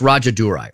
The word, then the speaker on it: Rajadurai: I think it's Rajadurai. Rajadurai. Rajadurai. I think the J is silent. R-A-J-A-D-U-R-A-I.